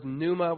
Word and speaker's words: pneuma: That word you can see at the root, the word pneuma with a silent P, that's a Greek word for spirit or wind or breath.